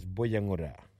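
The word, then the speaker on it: Buyungurra: Our local Mulgana word today is Buyangura.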